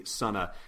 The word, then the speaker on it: Sana: And for what it's worth, just for the listener, because I personally was also surprised by the pronunciation, because when I saw Sunna, which is spelled S-A-N-A, it looks like it could be Santa or Sana. And I actually reached out to Jordan White, who's the editor of the Marvel Star Wars comics, and asked him, and he replied very kindly with the pronunciation key for her name. So it is officially Sunna.